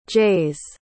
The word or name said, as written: jays